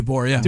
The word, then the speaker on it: DeBoer: Did I pronounce his name right? – Yeah, DeBoer.